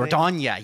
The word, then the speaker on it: Dordogne: It's like a Y-ish sound there. – Dordogne or something. – Dordogne.